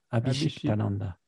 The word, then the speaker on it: Abhishiktananda: Abhishiktananda. Abhishiktananda. Ah bah alors, facile. – Au bout d'un moment. – Oui, oui, ça se, prononce comme ça. Ça se dit comme ça se prononce.